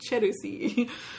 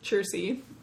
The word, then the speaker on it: Chersey: My instinct is to pronounce it in a Japanese like manner of pronouncing Chelsea, which would be like Cherusi, but it's Chersey.